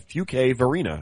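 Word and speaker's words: Fuquay-Varina: Fuque Verena? Oh, he put a pronunciation guide. Fuque Verena. I got it right.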